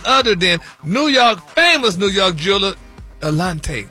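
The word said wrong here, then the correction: Eliantte: Now, Eliante. They say, Eliante? – Eliante. – Are they how you pronounce it?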